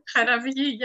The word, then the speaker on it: Charavgi: Charavgi, yes, thank you.